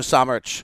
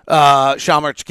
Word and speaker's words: Šamanić: And if Lowry can't go tonight, I'll bet you we see Luka Šamanić. Šamanić getting some plays. Shaw? I think that's right. Yeah. Shaw is how you pronounce the first part of it.